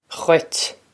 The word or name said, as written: dhuit